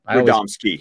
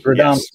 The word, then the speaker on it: Radomski: Radomski. – Radomski.